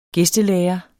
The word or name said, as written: gæstelærer